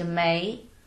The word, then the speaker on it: May: And then I changed into May because Victoria is too long for a middle school student to pronounce.